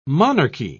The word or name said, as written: monarchy